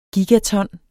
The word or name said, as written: gigaton